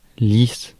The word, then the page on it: bald: /bɔːld/